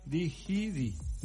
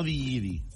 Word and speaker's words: Digidi: Se llama, se escribe así, Digidi. Con G de gato. – Digidi. – No digiri, digiri.